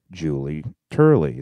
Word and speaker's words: Turley: Stark Reality with Julie Turley.